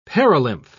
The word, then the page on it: perilymph: British English